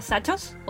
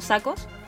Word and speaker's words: Zachos: hay una súper buena curva que se llama la curva de Sachos o Sacos. Se escribe Z-A-C-H-O-S,